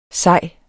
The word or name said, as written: sej